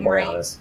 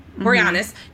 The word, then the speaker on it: Boreanaz: I never say his name right. – David Boreanaz. – Boreanaz. David Boreanaz as Angel.